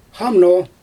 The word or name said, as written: xamno